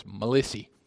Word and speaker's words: Melissa: Of course, in Whitwell, when I was small, you didn't pronounce that name Melissa. It was Melissy.